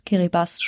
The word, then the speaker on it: Kiribati: Also da in dem Raum sind wir auf viele Atolle getroffen und vor allen Dingen auch in Kiribati schreibt man's, Kiribati spricht man das.